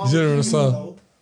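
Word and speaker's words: General Tso: Oh, man, I just been going here saying it wrong, pronouncing the T. – Let me get the General Chicken, bro. – How do you say it? – General So.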